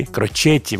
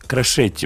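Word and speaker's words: Crocetti: Крочетти вообще, ну, Крошетти произносится Крошетти, по крайней мере, он сам произносит свою фамилию.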